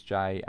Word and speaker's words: Jay: My name is Jay, and you can contact me on, uh, uh, on Twitter as J29ers.